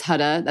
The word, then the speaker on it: Huda: I know it's Huda. That's how she says it. I always want to say Huda.